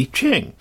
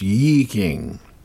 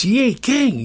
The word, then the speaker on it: I Ching: It's actually pronounced Yi Ching. And I get stuff over that, too. Some guy wrote, it's Yi Ching, Yi Ching, Yi Ching.